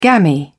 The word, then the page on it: gammy: British English